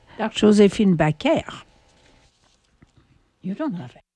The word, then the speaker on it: Joséphine Baker: Bon, maintenant nous allons écouter Joséphine Baker. Of course, I'm pronouncing her name the French way. It's Joséphine Baker, but in France, everybody called her. Tout le monde disait Joséphine Baker, pas Baker.